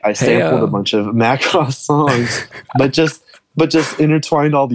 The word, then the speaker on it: Macross: Macross? Is that how you say it? – You got it.